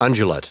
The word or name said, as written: undulate